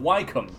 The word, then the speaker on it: Wycombe: The name is most commonly used to refer to St. Francis Dashwood's Order of the Friars of St. Francis of Wycombe. Or I could pronounce that right. W-Y-C-O-M-B-E.